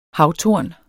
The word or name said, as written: havtorn